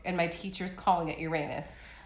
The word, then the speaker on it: Uranus: It is pronounced Uranus. – Or Uranus. I grew up calling it Uranus.